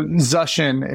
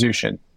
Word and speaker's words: Zuschin: So, Zuschin is the correct pronunciation the most common mispronunciation is Zushin, but probably because of like spell check, I get zucchini, which is just always.